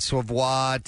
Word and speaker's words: Savoie: I guess that's how you say her name, S-A-V-O-I-E, Savoie.